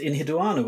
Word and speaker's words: Enheduanna: Speaking of Moana, it rhymes with Enheduanna, which was our first topic of the year, a Mesopotamian writer-type woman who was possibly the world's first published author.